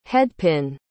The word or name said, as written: headpin